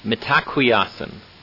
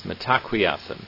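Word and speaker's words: Mitákuye Oyás'iŋ: Metaquiasan is how it's pronounced. Metaquiasan.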